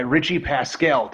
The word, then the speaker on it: Richi Paskell: right, folks, we're back with another episode of Skirmish Supremacy, and today I am joined by Richi Paskell.